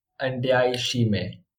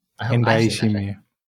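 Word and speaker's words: Ndayishimiye: of Nadai Shimie. Did I say that right? – Just about. I think they call him Ndai Shimie. – Ndai Shimie.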